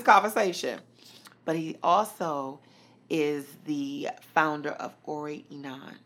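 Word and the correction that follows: Orieni: Did I pronounce that correctly? – Ori Eni. – Ori Eni. And that's O-R-I-E-N-I.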